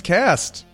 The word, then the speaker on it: Kasst: Is it just K-A-S-S-T? Or? – Oh, these are my friends, Cast.